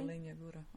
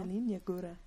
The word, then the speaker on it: Jelenia Góra: But it was in, I'm probably going to say it wrong, like Jelenia Góra or something. – Oh, Jelenia Góra. Uh-huh. – Jelenia Góra, yeah.